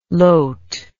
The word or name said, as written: lote